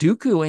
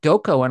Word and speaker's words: Dooku: And I was like, but he's like reading along with it, and he gets to Count Dooku, and he goes to Count Doko, and I'm like, "Dude, for an 8-year-old." Even though he pronounced it wrong, it was like right there. It was like one pronunciation, you know, one vowel off.